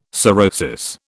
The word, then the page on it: sorosis: British English